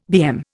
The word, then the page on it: BM: Indian